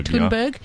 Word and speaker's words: Thunberg: How do you say her surname? Thunberg? – Thunberg has hit back at Donald Trump after he tweeted telling her to chill.